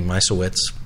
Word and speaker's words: Misiewicz: And then, of course, they've added two lefties already, one of which they added to the roster, and Anthony Misiewicz, I think I'm pronouncing that correctly, I asked. – Sound right to me. – We'll double check with him, but that's the pronunciation I was given,